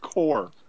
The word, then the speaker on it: corps: That's pronounced corps. – Corpse. – Not corpse. Not corpse. They're not looking for dead bodies. The core.